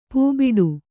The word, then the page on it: ಪೂಬಿಡು: pūbiḍu